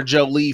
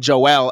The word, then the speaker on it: Joelle: First of all, I've been calling her Jolie for weeks, and her staff told me uh, they start saying Joelle. And I was like, wait a minute. You mean to tell me I've been pronouncing or enunciating um, someone who we really like name wrong. I mean, any person's name. And I was like, and when you look at her name, of course it's Joelle. It's not Jolie.